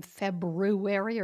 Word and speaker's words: February: Do you pronounce the R in February, or you just say February? That's what I say, and that's what most people say.